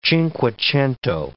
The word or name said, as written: cinquecento